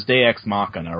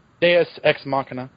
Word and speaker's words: Deus Ex Machina: Some people list this as a con, but I think the pronunciation is Deus Ex Machina, right? – Deus Ex Machina.